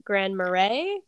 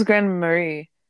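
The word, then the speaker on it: grand-mère: And then lastly, how do you say the French word for grandmother? Is it Grand Marais? Because that's how I was reading it. – I think it was Grand Marie.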